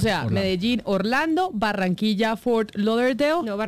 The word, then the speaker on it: Fort Lauderdale: Anuncia Avianca hoy Bogotá, ayúdeme acá a pronunciar Fort Lauderdale. – Yo es que Fort Lauderdale. – Bueno, ¿cómo es que es? – Fort Lauderdale.